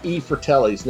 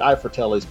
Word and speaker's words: I Fratelli's: Best place for any kind of pizzas is E Fratelli's. Now, it looks like I Fratelli's, but the I is actually pronounced E.